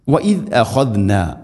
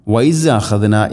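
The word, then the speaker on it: وإذ أخذنا: വഇദ് അഖദ്ന, എല്ലാ ക്ലാസ്സിലും പറയാറുള്ളത് പോലെ തന്നെ ദാലിൻ്റെ ഉച്ചാരണം വൈസ് അഖദ്ന എന്നല്ല, വൈസ് എന്നല്ല, വഇദ് അഖദ്ന, വഇദ്, വഇദ്, ദാ ദീദു അദ്, വഇദ് അഖദ്ന.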